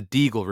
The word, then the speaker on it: Deagle: It's the Deagle Report with a D, not like a beagle, but rhymes with beagle.